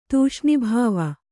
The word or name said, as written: ತೂಷ್ಣೀಭಾವ